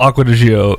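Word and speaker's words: Acqua di Giò: Okay, first of all, it's still Acqua di Gio. It's just because they're Italian and they can speak it quickly does not mean we're wrong. – I like how you're correcting them. No, we're saying it right. – I mean, we are. They're just saying it really fast.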